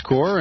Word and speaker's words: Corps: Visit the website World Service Corps, C-O-R-P-S, we would say Corps, but to help you spell it on the internet, worldservicecorps.us, to get more information.